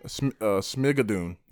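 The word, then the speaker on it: Schmigadoon: Smigadoon? Smigadoon? How do you pronounce it? – The hell is fine of? Say that again? – Smigadoon.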